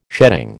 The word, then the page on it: sheading: American English